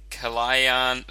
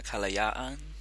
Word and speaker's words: Kalayaan: I think it might be Kalayaan, maybe. – That sounds more like it.